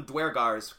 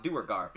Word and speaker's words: Duergar: We called them Dwergars because we had never heard anybody say it out loud, but apparently it's pronounced Duergar.